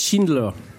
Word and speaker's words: Schindler: Ça se prononce pas chindé, ça se prononce Schindler.